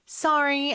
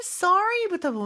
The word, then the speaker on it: sorry: Sorry, I missed your message. 이죠. 일단 sorry부터 보면요. 미국에서는 sorry 이렇게 어 발음이 나고요. 영국에서는 sorry 약간 오 발음이 더 Sorry. – 가까운 것 같아요.